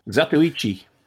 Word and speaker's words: Zatoichi: Number 9, is it Zatoichi? Is that how you say it? – Zatoichi.